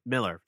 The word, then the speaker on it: Millar: The amount of Netflix money that went into their partnership with Mark Millar is in Miller.